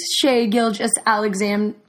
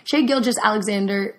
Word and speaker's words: Shai Gilgeous-Alexander: Shai Gilgeous-Alexander, really butchered that one. Let me try it again. Sorry, it's morning. Shai Gilgeous-Alexander was named to the world team for the Rising Stars Challenge.